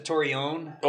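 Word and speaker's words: Torreón: So we pronounce it Torreon. – Okay, Torreon. – That's like the Spanish pronunciation.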